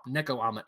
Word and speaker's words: Nekoama: The full title is Little Char and the Gang, a Pokemon Starter Trio Pet Parody by the artist Neo Kama. I'm pretty sure I said that right. Or no, I was wrong. Nekoama. It's spelled Nekoama.